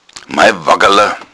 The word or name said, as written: waggala